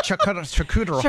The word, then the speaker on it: charcuterie: Hey, coming up at 8 o'clock, it's the debate at 8, and we're getting into charcuterie boards. – Is that how you say it? Charcuterie.